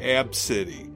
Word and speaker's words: Abcde: So, it's spelled A B C D E. – A B C. So write it down. A B C D E. She pronounced it supposedly Ab-City. – Ab-City? – Ab-City. – Ab-City. – Yeah. – A-B-C.